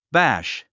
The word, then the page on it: bash: bǽʃ; バァッシ